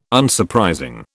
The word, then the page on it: Unsurprising: British English